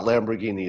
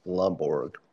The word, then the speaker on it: Lomborg: It's Lamborg, not Lamborghini. It's Lomborg.